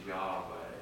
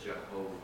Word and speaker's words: Yahweh: I believe now it's a mispronunciation, but we get Jehovah when, I guess now the modern understanding is it should be Yahweh instead of Jehovah.